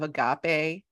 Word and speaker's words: Agape: Let me just say, she's the CEO of Agape. Am I saying that right? – Yes. – Thank you. Agape Match. Hard, soft G.